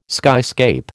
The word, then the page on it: skyscape: American English